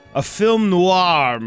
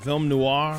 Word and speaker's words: film noir: Critics are describing this as more of a film noir. How do you say that? Film noir? – Film noir.